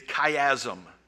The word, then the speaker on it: chiasm: It looks to me like it ought to be like a chia pet, but it's not. It's chiasm.